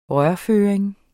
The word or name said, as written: rørføring